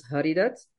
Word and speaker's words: Haridat: So, and he said, my name is Haridat, and in the Hindi way pronounced as Haridat, and Haridat means given by Hari, and which is Lord Vishnu.